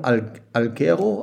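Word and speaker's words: Alghero: Ist es richtig ausgesprochen? – Algero. – Algero. Auf Sardinien.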